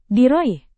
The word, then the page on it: diraih: indonesian